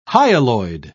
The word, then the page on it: hyaloid: British English